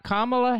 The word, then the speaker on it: Kamala: Number three, this is the top three, Kamala Harris.